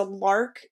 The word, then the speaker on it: Larq: But one that we wanted to recommend, and my husband and I actually have one, is a Lark self-cleaning water bottle. So it's spelled L-A-R-Q.